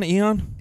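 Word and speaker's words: Aeon: Am I saying that right? Eon? – Eon, yeah.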